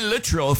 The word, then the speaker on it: Littrell: Brian Littrell from the Backstreet Boys. Yeah, that's how it's pronounced.